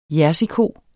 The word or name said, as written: jerseyko